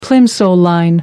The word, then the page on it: plimsoll line: American English